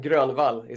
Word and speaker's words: Grönvall: And Grönvall is actually how you pronounce my last name, if you want to be really Swedish.